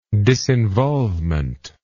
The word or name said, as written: disinvolvement